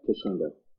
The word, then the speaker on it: Cachengo: Yep, Cachengo.